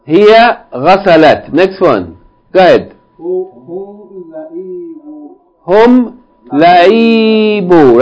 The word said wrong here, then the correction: لعبوا: Now, no long vowel on the second syllable. Huh? هم لعبوا.